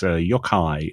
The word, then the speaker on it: yokai: And a lot of lovely lore about the Japanese yokai. I believe I'm saying that right, yokai? – Yokai.